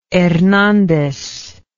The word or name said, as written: hernandez